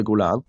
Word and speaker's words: Golan: Aber wie immer haben ja Damé nachher, oder wenn man es richtig ausspricht, ausspreche, der Produzent, Mr. Golan. – Mr. Golan, ja.